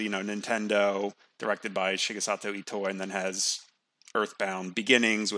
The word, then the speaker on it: Shigesato Itoi: So it's just, you know, directed by Shigesato Itoi.